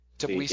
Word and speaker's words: Tbilisi: Tbilisi. Am I pronouncing that correctly? Tbilisi. – Yeah.